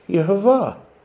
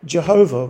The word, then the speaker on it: Jehovah: Now, the medieval English translators presumably didn't know that, so they said, oh, it's pronounced Yehovah. And, of course, in those days, the J had a Y sign, Y sound, so they said, God's name is Jehovah, or Jehovah with a J.